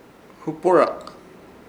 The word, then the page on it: huporak: Ishir Chamacoco